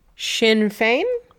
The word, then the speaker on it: Sinn Féin: Audience, it is S-I-N-N space F-E-I-N. – Sinn Féin? – Correct. – Yes, I knew it had a sh.